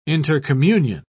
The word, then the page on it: intercommunion: British English